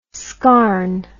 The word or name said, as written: scarne